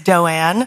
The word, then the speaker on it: Doan: I'll answer to anything, but it's Doanne. – Doanne. – And I say it's like Joanne, but with a D.